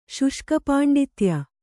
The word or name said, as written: ಶುಷ್ಕಪಾಂಡಿತ್ಯ